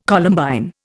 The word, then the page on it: Columbine: Indian